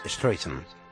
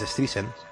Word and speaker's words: Streisand: Y Tim Cook ha arreglado ese error que tenía Siri cuando mencionaba y pronunciaba Barbara Streisand, porque ella quiere que se diga Streisand. – Claro. ¿Y cómo lo decía Siri? – Pues Streisand.